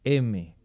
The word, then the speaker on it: heme: Y en cada una de esas unidades tenemos un grupo M, pero se escribe H-E-M-E.